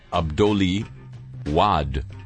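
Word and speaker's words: Abdoulaye Wade: Dear Harry, first the pronunciation, which you usually do so well. Well, there's some love right there. This is apropos of the, I believe, the Senegalese president I mentioned last week. It's Abdoli Wad.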